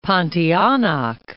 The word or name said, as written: Pontianak